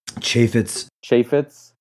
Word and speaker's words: Chaffetz: I know a little bit just because I know that the other day, Jason, how do you say his last name? Chaffetz? – Chaffetz.